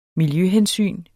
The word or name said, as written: miljøhensyn